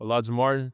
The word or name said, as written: Alijah Martin